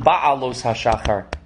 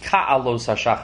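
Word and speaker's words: ka'alos hashachar (כעלות השחר): It's written ba'alos hashachar, but it's pronounced ka'alos hashachar,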